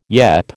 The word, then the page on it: yep: American English